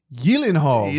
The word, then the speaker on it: Gyllenhaal: Starring Jake Gyllenhaal.